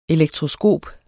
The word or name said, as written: ELEKTROSKOPER